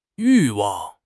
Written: 欲望

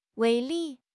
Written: 威力

